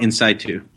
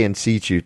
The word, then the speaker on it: in situ: Ductal carcinoma in situ. – Yes, I say in situ, but you know, I don't deal with this every day like you do.